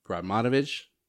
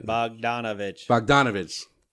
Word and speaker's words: Bogdanović: You got, for the Wizards, you got Bogdanović. – No, you didn't say that right. – Bogdanović.